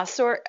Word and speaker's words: Sorcha: Did I get the name right, Sorcha? – Yeah, Sorcha is how Kernunos calls her, because he's using some ancient weird Irish dialect.